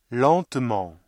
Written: lentement

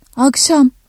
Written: akşam

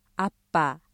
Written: 아빠

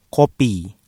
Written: kopi

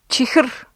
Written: чихэр

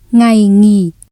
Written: ngày nghỉ

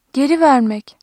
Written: geri ver-

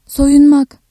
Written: soyun-

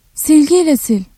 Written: sil-

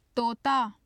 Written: tota

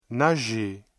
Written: nager

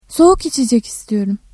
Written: soğuk

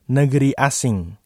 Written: negeri asing

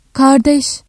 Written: kardeş

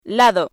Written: lado

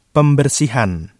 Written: pembersihan

